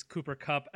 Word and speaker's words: Cooper Kupp: And that is Cooper Kupp out of Eastern Washington, whose name I probably also mispronounced because that's... – No, you got it right. – I got that one right.